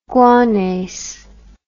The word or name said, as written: guanase